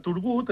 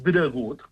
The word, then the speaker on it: Turgut: I doncs, els llatins, per referir-se a Turgut, acabaven pronunciant dregut, clar.